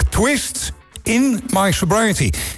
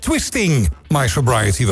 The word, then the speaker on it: Twist in My Sobriety: Namelijk Twist in My Sobriety. Ik heb altijd gedacht dat het Twisting My Sobriety was, maar het is Twist spatie in My Sobriety.